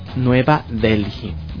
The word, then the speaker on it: Nueva Delhi: justamente por el gobierno de Nueva Delhi, así se pronuncia, así es.